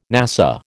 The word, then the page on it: NASA: American English